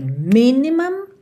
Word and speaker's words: minimum: E as sílabas tônicas são as primeiras nessas duas palavras. Então você tem minimum e maximum, ok? Minimum, maximum. A frase toda: we move in space with minimum waste and maximum joy.